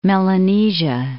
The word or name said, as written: Melanesia